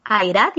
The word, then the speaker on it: Airad: Es Airad y Ayub, ¿cómo se pronuncia? – Airad y Ayub, Ah, toma. – como lo has dicho, sí.